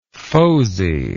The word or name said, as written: fozy